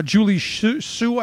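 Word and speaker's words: Julie Su: Julie Sue, I think, is that how you pronounce her name? – Yeah.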